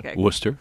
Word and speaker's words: Worcester: Worcester, yeah.